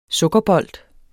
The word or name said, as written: sukkerbold